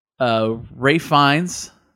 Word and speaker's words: Ralph Fiennes: Ralph Fiennes, I said it right that time.